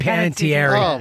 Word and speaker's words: Hayden Panettiere: Hayden Panettiere.